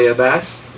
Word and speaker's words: Abass: Beside me is the lovely Kelly Abass. Did I say that right? – Yes, you did. – I didn't know if it was like Atlas, like Atlas, or... – Most people get it wrong, but mm-hmm.